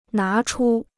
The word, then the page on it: 拿出: ná chū